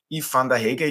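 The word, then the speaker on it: Yves Vanderhaeghe: Zunächst als Assistent tätig von Yves van der Hege. Ich hoffe, ich habe das jetzt richtig mhm. ausgesprochen. – Richtig. Ähm, dann, vielen Dank.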